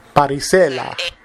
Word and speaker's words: Parisella: Parisella. Yep. – Parisella. That's how you say it in Italian.